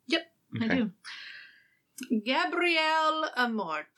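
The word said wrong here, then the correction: Gabriele: Gabriella. – That's how he says it. Gabriella? – It's spelled Gabrielle, but he says Gabriella.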